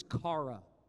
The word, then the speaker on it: chara: The word in the Bible for joy, the Greek word is chara. Chara, not Zdeno Chara, the hockey player. I spelled the same. Leaf fans don't like that name.